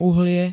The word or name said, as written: uhlie